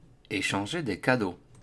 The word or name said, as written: Echanger les cadeaux